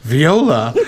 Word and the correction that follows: voila: You're like, what did you just say? like, vi- viola. Isn't that how you say it? it's voila.